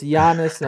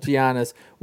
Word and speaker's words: Giannis: So it's Giannis, not Giannis.